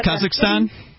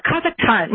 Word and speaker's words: Kazakhstan: So I wanted to bring up the Kazakhstan, how do you pronounce that, Kazakhstan? – I think it's Kazakhstan. – Yeah, Kazakhstan, right.